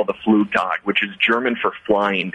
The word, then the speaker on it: Flugtag: All right, last thing I want to ask you about, Liam, is the flug tug. That's a Flugtag, yeah. – A Flugtag. Excuse, I didn't know the German pronunciation of this.